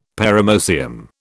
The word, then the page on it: paramoecium: British English